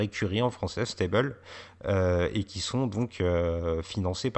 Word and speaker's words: STABEL: Il y a le fait que le programme de reconnaissance faciale que, pitch William Defoe au début du film s'appelle Stable, c'est un acronyme S-T-A-B-E-L, ça se prononce, il le prononce, en tout cas lui, Stable,